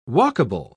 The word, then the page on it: walkable: British English